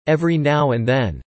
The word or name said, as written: every now and then